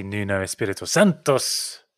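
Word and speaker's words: Nuno Espírito Santo: Because obviously Nuno Espirito Santos, Chris, fantastic manager. – Wow, what a pronunciation there. – Thank you. I tried to roll the R's.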